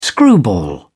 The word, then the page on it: screwball: British English